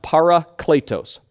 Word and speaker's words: parakletos: Now this word that we see all over the place here, helper, it comes from this Greek word that's pronounced parakletos. Parakletos. Everybody say it with me real quick.